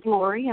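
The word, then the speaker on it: Lorie: My name is Lori, and I'm calling from Florida.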